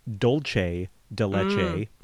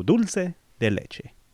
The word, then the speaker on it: dulce de leche: Trace the Sandman, Papa Smurf, because he kept saying dolce de leche. And dolce is how it would be pronounced in Italiano. Pero, you know, in speak of the Spanish... We say dulce. So dulce de leche.